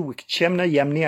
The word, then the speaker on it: Wikcemna Yamni: Wikchemna Yamni Akenwaji, and you can pronounce that just like it's spelt.